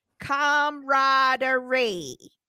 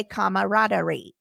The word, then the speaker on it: camaraderie: It has a silent A that trips people up. It is pronounced camaraderie. – That's what I said. – So you were right, but a lot of people say camaraderie.